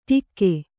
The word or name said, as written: ಟೀಕೆ